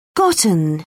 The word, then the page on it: gotten: British English